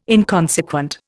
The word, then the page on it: inconsequent: Indian